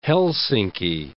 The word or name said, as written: Helsinki